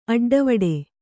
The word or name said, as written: ಅಡ್ಡವಟ್ಟೆ